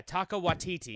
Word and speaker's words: Taika Waititi: Yeah, Taika Waititi. – That's amazing. – Is how you pronounce it.